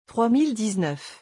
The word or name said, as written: 3019